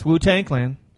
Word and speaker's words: Wu-Tang Clan: Yes, Wu-Tang Clan.